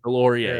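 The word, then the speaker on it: Deslauriers: What a fight, too, by Nick Dislorius. – That was an insane fight. – Delorier, yeah. I'm sorry. I mispronounced that.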